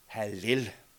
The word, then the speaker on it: Hallel: Vi kender ordet fra Halleluja. Vi udtaler det lidt anderledes, så I kan høre sammenhængen. Hallel.